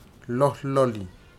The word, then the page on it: lohloli: futuna aniwa